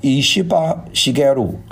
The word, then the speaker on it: 石破茂: 那个和他对决的这个呢叫石破茂啊，石破茂的日本读音叫伊西巴西盖鲁啊， 1957 年生的，年纪不小了，老牌政客，在日本从政 46年啊，在日本的议会，在日本的几个内阁里面都担任过重要的这个工作。